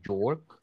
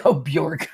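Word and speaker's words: Björk: B-J-O-R-K, Jork. – B-J-O-R-K, Bjork.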